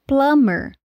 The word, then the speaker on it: plumber: Só que tem algumas exceções, como no caso de plumber, encanador, em que essas combinações das letras MB está no meio da palavra, mas esse B fica silencioso. A escrita é plumber, mas a pronúncia é plumber.